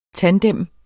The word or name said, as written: tandem